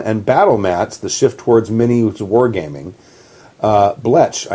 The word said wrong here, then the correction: blech: I never liked it. – I think it's pronounced blech. – Blech. – But it's spelled blech.